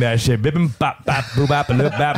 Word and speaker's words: bibimbap: And no white person ever pronounced bibimbap right. It's like bibimbap.